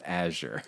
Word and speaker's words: Azure: The number of people who say Azure, and I'm like, it's Azure. The word is pronounced Azure.